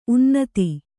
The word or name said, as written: ಉನ್ನತ